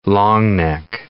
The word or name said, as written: longneck